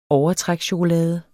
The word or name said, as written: overtrækschokolade